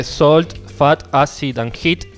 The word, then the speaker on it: Salt, Fat, Acid and Heat: La otra vez recomendaste eh, Salt, Fat, Acid and Heat. Bueno, ese... Lo pronuncié como el... No, no, está perfecto como lo pronunciaste.